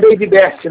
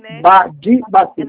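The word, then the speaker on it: Bady Bassitt: Não é Baby Best, não, porque aqui nós somos brasileiros, não somos americanos. É Badi, Badi, Bacete.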